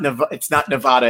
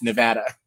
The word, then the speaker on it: Nevada: I saw a bumper sticker that somebody posted today, and it's not Nevada, it's Nevada.